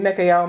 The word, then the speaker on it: Mikael: It's a different pronunciation of it, um, but it's Mikael, Mikael, Mikael. he who is like unto God. The El part is, is an ancient um, Hebrew name for God.